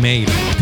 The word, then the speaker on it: Mhaol: כותבים את זה M-H-A-O-L, אבל מבטאים מייל.